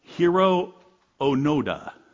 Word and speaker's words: Hiro Onoda: Hiro Onoda.